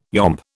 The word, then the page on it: yomp: British English